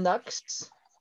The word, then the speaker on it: axe: Un axe. – Comme ça? – Oui. It's the same pronunciation as the English axe. – An axe. – Yes, same pronunciation as the word, the English word axe.